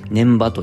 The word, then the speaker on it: 根場: 年場というのは漢字で書くと根っこの根に場所の場って書いて、えー、まあ、根場って書くんですけれども、年場と読みますね。